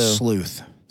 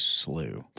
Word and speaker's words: slough: And or so sleuth. – sleuth. I like that. Sleuth. But the proper uh British or Scottish way of pronouncing that is slew Slew.